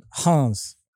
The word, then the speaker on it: Reims: It starts with an R, but I know you just pronounced it completely differently. But how is it? – Hans. – Jeez. All right, Hans, and it's spelled R-E-I-M-S, but he's playing on loan at Hums, and he's scoring into the teens now in terms of goals.